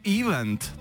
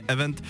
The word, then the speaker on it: event: Martin píše, dobrý den, často slyším anglické slovo event vyslovovat jako event. To je ale špatně. Správně event s důrazem na druhou slabiku.